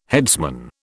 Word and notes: headsman: British English

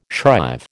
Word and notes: shrive: American English

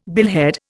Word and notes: billhead: Indian

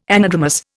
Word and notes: anadromous: Indian

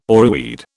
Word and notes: oreweed: British English

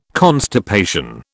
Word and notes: constipation: British English

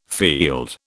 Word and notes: field: British English

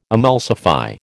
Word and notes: emulsify: American English

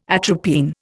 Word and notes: atropine: Indian

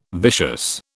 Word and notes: vicious: British English

